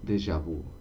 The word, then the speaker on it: déjà vu: ¿Cómo se pronuncia? Déjà vu. Déjà vu.